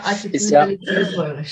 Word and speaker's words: Acetylsalicylsäure: Aspirin, Acetylsalicylsäure, wenn ich das richtig ausgesprochen habe, kann wieder noch korrigieren. – Ja, Acetylsalicylsäure, stimmt.